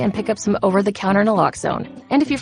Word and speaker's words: naloxone: For the record, it's Naloxone. Nuh, like, is Naloxone addictive? Nuh-uh. Locks like Naloxone keeps humans safe like locks keep bikes safe, and own like Naloxone can save lives in our very own communities.